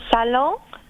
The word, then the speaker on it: salon: C'est salon. C'est prononcé de cette façon parce que c'est français.